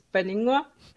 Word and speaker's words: Paninnguaq: Yeah, so the P is like silent, it's Beningwa, and it means the sweet daughter in Greenlandic.